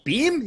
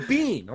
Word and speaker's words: Beam: He's like, bro, he calls it Jim Beam. I don't think he knows he's saying it wrong, but it's like, I'm all Beam. He's all, no, Bean.